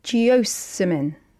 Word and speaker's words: geosmin: geosmin. – Geosmin. – Geosmin. – Geosmin. Spell that for me, please. – G-E-O-S. – Geos. – M-I-N.